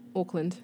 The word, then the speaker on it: Auckland: Auckland. – Auckland. – Auckland. Yeah, people are like, are you saying Auckland? I'm like, no, it's Auckland.